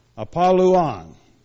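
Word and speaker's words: Apollyon: The Y is pronounced like a U. Apolluon.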